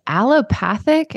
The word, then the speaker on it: allopathic: Well, many people call you the true mother of holistic medicine and a true pioneer when it comes to, is it allopathic? Is that how I pronounce that? – Yeah, you got it.